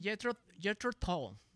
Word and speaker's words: Jethro Tull: ¿Se pronuncia Jethro? – Jethro Tull. Okay. Jethro Tull.